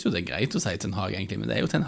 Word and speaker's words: Ten Hag: For at egentlig på sånn hollandsk så skal vel Ten Hag uttales Erik Ten Hag med en sånn rrr-lyd der han men samtidig,